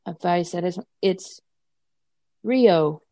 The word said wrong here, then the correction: Riho: from AEW. – Oh, Riho, yeah.